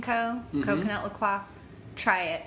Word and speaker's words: La Croix: Yeah, people say La Croix, but, you know, being bilingual, it's actually La Croix.